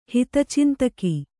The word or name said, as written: ಹಿತಚಿಂತಕ